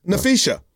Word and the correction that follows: Nafisa: It's Nafisa. – I call it Nafisha. – That's not how it's pronounced.